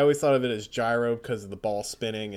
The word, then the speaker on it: Gyro: Yeah, the easy way to look at it is look at the kana, fortunately. And it is, in fact, Gyro. Like, phonetically, it is Gyro Tsiperi.